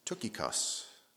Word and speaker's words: Tychicus: Is it Tychicus? Tychicus? I actually looked it up. It's Tychicus. Tychicus.